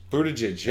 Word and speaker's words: Buttigieg: Buttigieg, yeah.